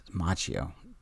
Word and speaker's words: Macchio: Macchio.